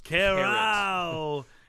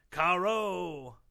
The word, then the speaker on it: carreau: Carrot. It's pronounced carreau. It's a special feat in which the shooter knocks the opponent's bowl out while leaving his bowl very near to the impact. It's pronounced caro.